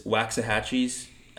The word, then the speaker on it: Waxahatchee: the listener is Waxahatchee's album St. Cloud um, she's a kind of folk uh, what's Americana What's the, artist.